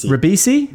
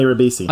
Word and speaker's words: Ribisi: Ribisi. Yep. – Am I saying it right? – Yep. Giovanni Ribisi.